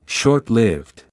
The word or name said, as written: short-lived